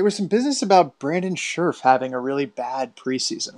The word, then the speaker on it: Scherff: But the man himself says Scherff, like a sheriff.